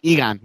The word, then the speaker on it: Egon: Egon? Is that how you pronounce it? Egon.